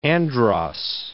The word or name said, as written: Andros